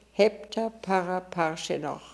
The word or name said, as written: Heptaparaparshinokh